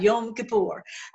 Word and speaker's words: Yom Kippur: You want to say that right, Yom Kippur.